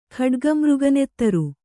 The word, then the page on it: ಖಡ್ಗಮೃಗನೆತ್ತರು: khaḍga mřga nettaru